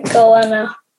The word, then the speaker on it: goanna: And you said he hunts fish, kangaroo, and then there's a word here that starts with N that I don't know how to say. Can you tell me how to say it? And what's that? – It's a goanna.